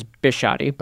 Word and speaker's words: Bisciotti: And forgive me, I don't know him, so did I mispronounce his name? Is it Biscotti, Biscotti? – I've always thought it was Bisciotti, but Bisciotti.